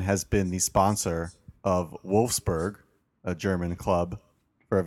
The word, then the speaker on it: Wolfsburg: I will say Wolfsburg.